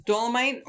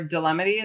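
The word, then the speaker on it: Dolemite: I mean, look, Dolomite or Dilemity, as I was calling it until I was informed differently.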